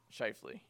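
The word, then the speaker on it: Schauffele: Shifley.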